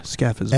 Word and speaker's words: scaphism: Scafism? – Scafism. Time out. Spell it, please. Yeah, scafism. – S-C-A-P-H-I-S-M.